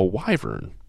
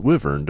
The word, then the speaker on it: wyvern: and their family crest depicted a wyvern, or a wyvern, depending on your pronunciation, which, as dedicated listeners may recall, we described earlier, which is a bat-winged, dragon-like legendary creature, basically.